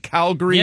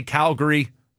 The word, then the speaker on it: Calgary: I have to consider calling it Calgary. – Yeah, you do. – Because I do have a rule that I pronounce the city like the locals pronounce the city. It's New Orleans. It's Louisville, whether you like that or not. And if they say Calgary, I might have to change it to Calgary.